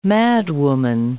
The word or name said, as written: madwoman